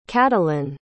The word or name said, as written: cadilyn